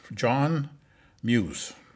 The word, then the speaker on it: John Mues: This interview today is John Mues.